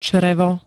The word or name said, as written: črevo